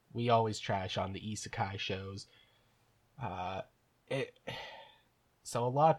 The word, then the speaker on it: isekai: I've always said it Aikiza, so no, isekai.